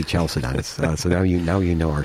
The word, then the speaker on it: Chalcedon: Chalcedon, spelled with a CH, but it's pronounced like a K.